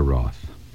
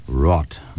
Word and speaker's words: wrought: But that is incorrect. Never wroth. Wrought. W-R-O-U-G-T-H. W-R-O-U-G-H-T.